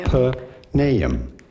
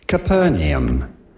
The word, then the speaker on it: Capernaum: Capernaum. – Capernaum.